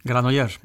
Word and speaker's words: Granollers: Granollers Barcelona.